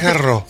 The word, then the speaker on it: Garros: Garros, creo que es Garros. – Sí, por la S al final en francés la no se pronuncia. La S no se pronuncia.